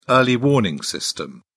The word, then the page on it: early warning system: British English